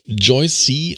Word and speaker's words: Joyce: Sie nennt sich, also sie wird Joyce geschrieben, aber Joyce C. ausgesprochen, habe ich mir sagen lassen, in Brasilien.